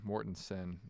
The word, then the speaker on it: Mortensen: So, fun fact, Adriana Mortensen, which is spelled with an E. It is an E in my phone. I just learned. Is like Sen, Mortensen. Listeners are like...